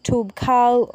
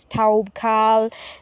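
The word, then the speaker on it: Toubkal: There's Toub Kal, or some would pronounce it as Taub Kal.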